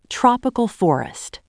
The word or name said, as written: tropical forest